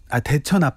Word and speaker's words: 대천: 저는 대전 앞바다지만 하셨어요. 아 그렇군요. 대천 앞바다. 예, 대천 앞바다라는 노래가 있었으면 그거 청해 주셨으면 좋을 뻔했는데 대천 앞바다 이것도 발음 괜찮은데 대천 앞바다 여수는 지금 그 세계 섬 박람회가 열리고 있죠.